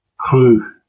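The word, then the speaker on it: Qugh: Yeah, so the next one, yeah, is the Kruh class, spelled Q-U-G-H. I think we've got that pronunciation because there's a note on the Ex Astra Scientia page about it that it says that this means disaster, and it's also Kruh's name in Klingon. So I'm going to go with that pronunciation for it.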